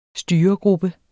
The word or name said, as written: styregruppe